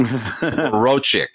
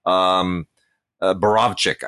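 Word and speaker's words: Borowczyk: Borochik. Yeah. Borochik, the famous exploitation director. Borovchik, I guess, is the correct pronunciation.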